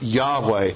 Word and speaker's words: Yahweh: When you go to the Septuagint, the Greek translation of the Old Testament, you will find in the Hebrew the covenantal name for God, YHWH. It's pronounced Yahweh.